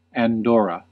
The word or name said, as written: Andorra